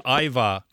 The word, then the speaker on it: ajvar: It's called Ajvar. Which I hadn't heard of, but apparently... – Yeah, I know what that is. I've got a Serbian background, and it's pronounced Ivar, actually.